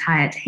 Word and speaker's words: Hiut: I think it's pronounced Hiut, H-I-U-T.